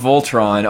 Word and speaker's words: V-07-TRON: But if you could do that, it spawns this creature called Voltron, it's called Voltron, although it's spelled V-07-TRON.